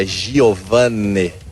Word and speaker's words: Giovane: C'è una difficoltà per chi come me racconta le partite, ed è il tuo cognome, perché l'accento c'è o non c'è? Giovane, Giovane, c'è chi dice, io ti chiamo Giovane, poi qualche collega mi dice no, Giovane, in telecronaca, in radiocronaca, vogliamo definitivamente ufficializzare la pronuncia esatta? – Bueno, allora, in portoghese la pronuncia esatta è Giovane.